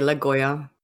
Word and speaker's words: Lagioia: That's how you say it. I would say Lagoya. L-A-G-I-O-I-A.